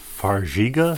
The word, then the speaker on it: Farxiga: It's Farxiga.